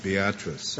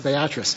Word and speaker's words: Beatrice: It's named after a little town in Nebraska, and I think it's pronounced Beatrice. – Beatrice. Mr. Chief Justice, I will pronounce it Beatrice.